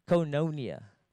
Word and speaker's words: koinonia: So the Greek word for fellowship is koinonia.